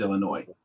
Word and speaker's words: Illinois: It is Illinois.